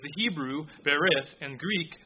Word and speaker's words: berith: It's berith, if I'm pronouncing it right, B-E-R-I-T-H, and that's Hebrew. The other word is a Greek word, and it's diatheke, D-I-A-T-H-E-K-E.